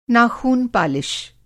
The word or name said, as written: नाखून-पालिश